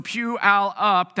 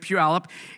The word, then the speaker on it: Puyallup: But Puyallup, the pronunciation, the phonetic way of saying it is just like you're sitting in a church pew, although we don't really have church pews anymore. And then you've got your Uncle Al who's going to get up, right? So at the closing of the sermon. So Puyallup, that's how you say it, Puyallup.